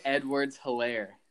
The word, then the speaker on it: Clyde Edwards-Helaire: Clyde Edwards-Helaire.